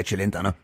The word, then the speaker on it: Celentano: Ich hoffe, Sie unterstützen mich, Herr Celentano. Habe ich es richtig gesagt? – Fast, ja. Es ist besser geworden als wie der erste Versuch, ja.